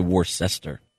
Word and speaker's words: Worcester: There's actually no H in that. It's actually Worcester.